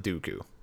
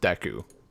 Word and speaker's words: Deku: If someone told me that I was doing it up until like 4 years ago, so like 20, maybe 19, like I pronounce it Dooku, the Great Dooku Tree. – Count Dooku. – I don't know why. Like for some reason, my kid brain, like playing Ocarina of Time. I am like, there's no way this could possibly be pronounced Deku. That's dumb. It's Dooku, obviously.